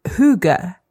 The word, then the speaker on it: hygge: And the letter Y is pronounced as a U. So it's hygge. Hygge. – That's the H-Y-G-G word.